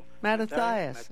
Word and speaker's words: Mattathias: His name is kind of difficult to pronounce, but it spells M-A-T-T-A-T-H-I-A-S. So Mattathias. – Mattathias. Yes, that's him.